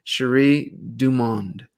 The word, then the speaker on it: Sheri DuMond: Please welcome to the Power of Love show, Sheri DuMond.